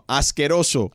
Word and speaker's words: asqueroso: Tú eres un asqueroso. – Sí. Muy bien. Yeah. – At least he admits it. – Muy bien. – Oh. – What's escuso? – Escuso? – Sí. – No, asqueroso.